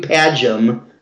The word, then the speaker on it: Padgham: And Hugh Padgham, is that how you say his name? – Yeah.